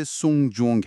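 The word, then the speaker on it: سونگ جونگ (送终): کلمه ساعت یعنی جونگ. تلفظش خیلی شبیه به عبارت سونگ جونگ هست.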